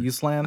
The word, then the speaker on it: Uslan: Uslan.